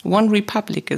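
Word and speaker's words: OneRepublic: Ich habe den Titel gewählt, weil es eine Passage aus einem Lied von One Repub Republic, One Republic, oh Gott, ich kann es nicht aussprechen, doch, One Republic ist.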